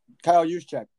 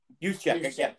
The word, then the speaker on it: Juszczyk: Kyle Juszczyk. – Juszczyk.